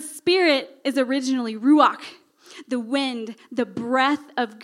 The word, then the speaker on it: ruach: In the Old Testament, the Holy Spirit, the word Holy Spirit is Ruach. Now you have to say it like you have something stuck in the back of your throat. So everyone say with me, Ruach.